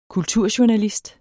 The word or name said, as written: kulturjournalist